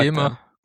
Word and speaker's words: Gemma: Okay, also das Google Gemma sagt über sich selber, dass es wie ein hartes G ausgesprochen wird. Das heißt, es ist nicht ganz klar jetzt. So. Gemma sagt Gemma.